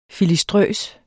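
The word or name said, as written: FILISTRØST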